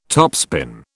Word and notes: topspin: British English

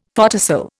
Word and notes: photocell: Indian

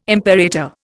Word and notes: imperator: Indian

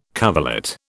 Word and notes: coverlet: British English

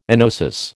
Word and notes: enosis: American English

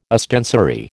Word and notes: ostensory: American English

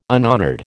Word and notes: unhonoured: American English

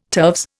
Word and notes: turves: Indian

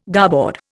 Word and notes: garboard: Indian

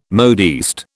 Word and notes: modiste: British English

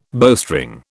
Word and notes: bowstring: British English

